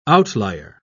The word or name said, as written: outlier